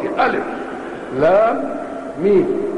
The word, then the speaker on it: الم: فما الذي دلنا على أن هذه تنطق في البقرة ألف لام ميم وتنطق في هذه السورة بألم؟ إنه سماع من المبلغ، سمعناها هكذا فقرأناها هكذا.